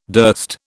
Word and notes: durst: British English

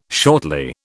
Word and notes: shortly: British English

